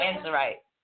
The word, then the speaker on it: amazonite: Amazonite. Amazonite.